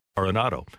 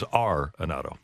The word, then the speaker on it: Arenado: I asked him, because I didn't know how he wanted his name pronounced. He said, well, I don't really care, but our family, Arenado. – Yeah. – So that's the correct pronunciation is Arenado.